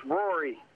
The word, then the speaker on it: Rory: It's Rory. – Rory? – Yeah, what's like the golfer, except I don't have his bank account.